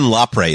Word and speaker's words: Lapre: I believe it's Don Lapre is how it's pronounced, yes.